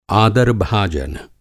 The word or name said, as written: आदार-भाजन